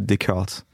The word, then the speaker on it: Descartes: Alors la prononciation est assez simple, c'est Descartes.